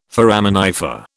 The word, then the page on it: foraminifer: British English